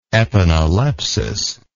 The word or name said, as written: epanalepsis